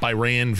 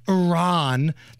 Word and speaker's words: Iran: He got called out by this journalist from Iran that he was saying the name of the country wrong. – You're pronouncing our country's name wrong. Our country is named Iran, not Iran.